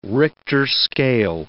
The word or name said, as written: Richter scale